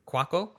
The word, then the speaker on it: Cuoco: However, and this is a sitcom that airs on CBS, and it stars Johnny Galecki, Kaylee Cuoco, is that how you pronounce it? – Cuoco.